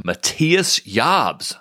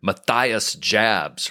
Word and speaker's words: Matthias Jabs: One of my favorite guitar players of all time, Matthias Jabs, not to be pronounced Matthias Jabs, right? That would be a newbie mistake.